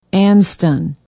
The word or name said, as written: anston